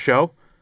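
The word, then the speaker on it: Chão: And it's Portuguese, so it's show. That's how you pronounce it, but it's spelled like chow.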